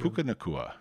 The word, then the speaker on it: Puka Nacua: Puka Nacua.